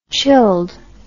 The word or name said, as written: chilled